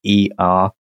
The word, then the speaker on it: Aether: Next up, you can get Rise of Aether, I think I'm saying that right. Aether. – Yeah, it looks right to me.